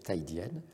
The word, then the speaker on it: Kaidian: Ça s'écrit Kaidian, mais ça se prononce Taïdian.